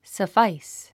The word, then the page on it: suffice: səfáis; サファイス